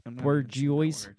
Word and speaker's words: bourgeois: Bourgeois. – What's that? Bourgeois.